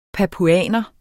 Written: papuaner

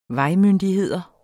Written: vejmyndigheder

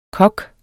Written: kok

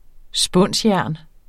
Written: spunsjern

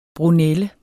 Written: brunelle

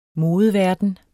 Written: modeverden

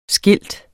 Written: skilt

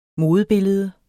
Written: modebillede

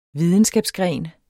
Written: videnskabsgren

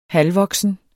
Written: halvvoksen

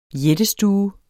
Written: jættestue

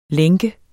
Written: lænke

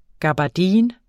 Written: gabardine

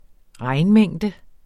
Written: regnmængde

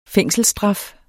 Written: fængselsstraf

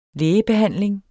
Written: lægebehandling

